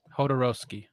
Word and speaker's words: Jodorowsky: Jodorowsky.